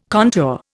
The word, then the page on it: contour: Indian